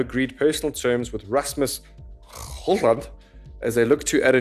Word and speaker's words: Højlund: So I believe, Sean, the pronunciation is Rasmus Højlund, so that you don't pronounce the D.